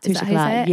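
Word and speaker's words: Touche Éclat: Touche-éclat, yes.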